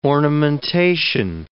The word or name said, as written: ornamentation